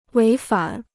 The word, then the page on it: 违反: wéi fǎn